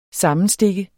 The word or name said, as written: sammenstikke